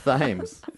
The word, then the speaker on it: Thames: I fucked up saying it wrong. – That's what I'm... Oh. – Thames.